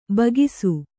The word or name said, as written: ಬಾಕಿ